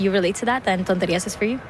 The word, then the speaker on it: Tonterías: Tonterías. – Tonterías.